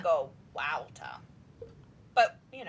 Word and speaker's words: WAWTA: Wouta is pronounced Wouta. Like, Wouta. – Someone was very upset at us recently because we spell the name of our podcast W-A-W-T-A and pronounce it Wouta.